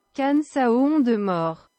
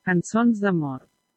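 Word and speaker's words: Cançons de Mort: Here's maybe a better pronunciation. – Canções de Mort. – There you go.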